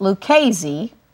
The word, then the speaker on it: Lucchese: When you totally butchered my Italian name, Lucchese, I said, the correct pronunciation is Lucchese, I knew you did not speak Italian naturally.